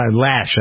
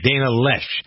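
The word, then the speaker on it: Loesch: But his replacement is a young female, moderately conservative, Dana, Lash, I believe is how you pronounce her last name. – Her name is pronounced Lesh. – Lesh. Dana Lesh. L-O-E-S-C-H, I believe.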